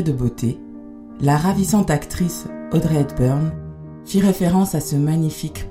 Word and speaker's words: Audrey Hepburn: On va écouter un texte qui a été lu à l'enterrement d'Audrey Hepburn, c'est ça? Ça se prononce comme ça? – Oui, que vous avez choisi.